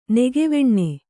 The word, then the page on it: ನೆಗೆವೆಣ್ಣೆ: negeveṇṇe